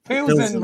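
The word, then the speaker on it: Plzeň: Or Victoria Plzen. – Pilsen, Ross. It's where beer's from.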